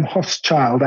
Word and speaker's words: Hochschild: It's H-O-C-H-S-C-H-I-L-D. So Adam Hochschild, I think is how you, or Hochschild, I think is how you pronounce it.